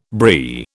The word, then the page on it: Brie: British English